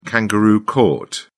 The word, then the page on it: kangaroo court: British English